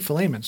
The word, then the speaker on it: Philemon: As long as you put the stress and emphasis on the li, it's okay. You're saying it right. It's Philemon or Philemon. But not Philemon. So if you've said it like that, you're saying it wrong.